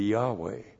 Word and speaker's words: Yahweh: If you actually pronounced this, it would be Yahweh.